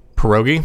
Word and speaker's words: pierogi: P-I-E-R-O-G-I. – Pierogi? – Pierogi, thank you.